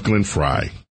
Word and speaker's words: Glenn Frey: And yes, it's pronounced Glenn Frey. I know. Sometimes I just read it the way I see it and just don't even think about it.